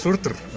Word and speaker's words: Surtr: Y los nombres, de hecho, no es Surtur, es Surtr. O sea, sí.